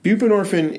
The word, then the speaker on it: buprenorphine: Buprenorphine.